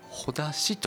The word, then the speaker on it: 絆: 実はほだしという、はひふへほ、ほだしという読み方があるそうなんですよ。